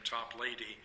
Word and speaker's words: Toplady: The hymn writer, Augustus Toplady, remember his name is spelled T-O-P-L-A-D-Y, and we're tempted to see L-A-D at the end and pronounce his name Toplady, but it was Toplady,